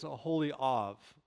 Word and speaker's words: Aholiav: Now, his name is Aholiav. There's a V sound at the end. We, we translate it in English to be, but it's actually a holy of.